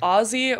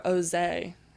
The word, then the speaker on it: Ozee: I either get Ozee or Ozee, and they think it's like super French or super classy. Oh, doesn't it look super French, though? – I think it is French, but it's not pronounced that way.